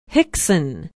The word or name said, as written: hixon